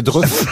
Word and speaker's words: Dreux: Vous le prononcez mal, mais c'est Dreux.